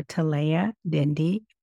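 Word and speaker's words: Talaya Dendy: Simply search for Talia Dindi, BCPA, that's spelled T-A-L-A-Y-A, last name D-E-N-D-Y, and BCPA.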